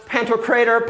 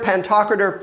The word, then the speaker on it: pantocrator: Since Jeff doesn't know, it's pronounced pantocrator, pantocrator, pick one, pan, all, kratos.